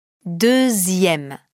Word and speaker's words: deuxième: Il semble qu'avec ces trois mots, dixième, sixième et deuxième, on ait gardé la prononciation Z qu'on entend quand on fait la liaison entre 10, 6, 2 et un mot qui commence par une voyelle.